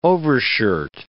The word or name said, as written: overshirt